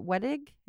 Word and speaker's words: Wettig: And Patricia Wettig, is mm-hmm.